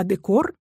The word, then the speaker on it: декор: Интересно также, что слово «декорация» мы произносим через «е», а «декор» — через «э».